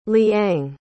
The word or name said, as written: liang